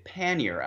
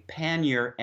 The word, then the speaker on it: pannier: This is an old word in English, which is why we pronounce it pannier, and that is to say with English phonetics. We only give in our dictionaries two phonetic transcriptions. They're very similar if you listen carefully, pannier and pannier.